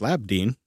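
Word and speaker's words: Labdien: Coming in from Podverse saying, good day, Labadeen in Latvian, which is pronounced Labdeen. Hopefully I got that kind of right.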